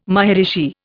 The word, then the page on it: maharishi: Indian